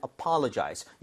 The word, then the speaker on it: apologize: 그래서 apology는 사과, 그리고 사과하다란 동사는 apologize. 얘는 강세 어디 줄 것 같아요? – Apologize. – 똑같습니다. 여기다 강세를 주고 강세를 넣고 연습해야 오히려 스펠링이나 이런 것들 헷갈리지 않을 수 있거든요.